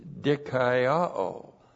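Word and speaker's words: dikaiō (δικαιόω): The word is dikaiō in the Greek. Looks like this, D-I-K-A-I-O, long O.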